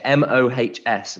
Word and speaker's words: Mohs: I, have never come across this Mons Mohs scale of hardness before. – I don't know how you say it. M O H S Yeah. Mohs the Mohs hardness scale uh, yeah, diamond is number one.